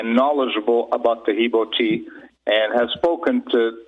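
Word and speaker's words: Taheebo: Tahibo is spelled T-A-H-E-E-B like boy, O. So the first word is Tahibo. The second word is T, T-E-A.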